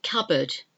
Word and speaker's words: cupboard: And then P in cupboard. This of, often gets foreign speakers a little bit confused. Cupboard is spelled C U P B O A R D, cup board, but it's pronounced cupboard cupboard.